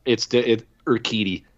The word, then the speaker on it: Urquidy: Urquidy.